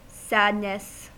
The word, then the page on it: sadness: American English